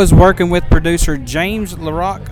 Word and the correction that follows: Lockery: Hope I pronounced his last name right. LaRay? – James Lockery. – Lockery, yeah.